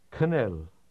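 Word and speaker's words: kanel (գնել): The Armenian words to buy and to do sound almost alike. Listen to them together, paying attention to the K sound at the beginning of to buy. – Anel, kanel.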